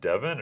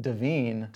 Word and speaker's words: Devine: is it pronounced Devin or Divine? Is there any like? – Yeah, I think initially I was saying it Devine, but then I was like, maybe it is just Devin. – Yeah, we'll just say Devin because that's a lot easier. It's spelled D-E-V-I-N-E, which probably shows our confusion.